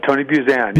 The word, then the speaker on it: Buzan: And then your friend Tony was it pronounced Buzan? – Uh, Tony Buzan. – Buzan. Yeah. He he kind of plugged you in, and and so let's start from there.